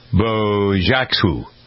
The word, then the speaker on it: Bojaxhiu: I believe the pronunciation is Bojaxhu. All right. Agnes, you know how to spell that. Gongza, G-O-N-X-H-A. Last name spelled B-O-J-A-X-H-I-U.